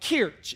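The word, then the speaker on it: Kirche: but it's like Kirch. It's K-I-R-C-H-E.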